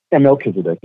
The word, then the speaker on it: Melchizedek: Thou art a priest forever after the order of Melchizedek. – Mm-hmm. – Chizedek. – Melchizedek. Usually the CH is pronounced as C only.